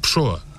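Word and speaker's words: Pessoa: Η λέξη Πεσόα που λέμε εμείς προφέρεται στα πορτογαλικά ψώα.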